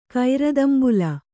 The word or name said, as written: ಕೈರದಂಬುಲ